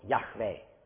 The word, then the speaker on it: Yahweh: We know that the correct pronunciation in the past was Yahweh. We know that by transcriptions with Greek letters from antiquity, and therefore we can reconstruct the original pronunciation.